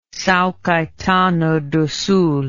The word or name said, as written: Sao Caetano do Sul